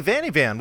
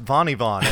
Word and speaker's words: Vanny Von: And then when I would go into people's chats and say hello or whatever, be like, oh hey, Vanny Van, what's up? And I'm like, actually, it's pronounced Vanny Von, and like the reactions I get, like oh, um, oh, sorry.